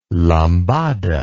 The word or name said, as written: lambada